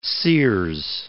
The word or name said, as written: Sears